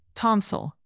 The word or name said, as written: tonsil